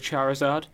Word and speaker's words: Charizard: I will have to go with Charizard.